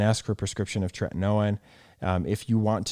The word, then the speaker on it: tretinoin: What are your thoughts on retinol versus like a retinoid, like Retin-A, or like what's the generic, the tretinoin, am I saying that right? – Tretinoin.